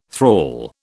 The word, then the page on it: thrall: British English